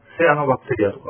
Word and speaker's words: シアノバクテリア: で、世の中にはまあ本がいろいろこう書かれてるんですが、この免疫とこのシアノバクテリア、光合成細菌と非常にこう深い関係がある。まあ、それはミトコンドリアという形なんですけどね。で、さらに、このシアノバクテリア、セアノバクテリアとか発音するんですけど、米ぬかを使ってですね、簡単にまあ家庭でも培養ができるということで、非常に面白いものですね。